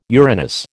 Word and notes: Uranus: American English

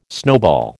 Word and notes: snowball: American English

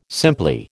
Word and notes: simply: American English

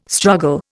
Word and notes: struggle: Indian

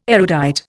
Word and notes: erudite: Indian

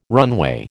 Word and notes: runway: American English

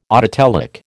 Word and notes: autotelic: American English

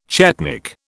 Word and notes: chetnik: British English

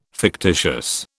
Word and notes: fictitious: British English

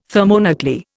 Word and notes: thermonuclear: Indian

